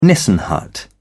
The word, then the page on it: nissen hut: British English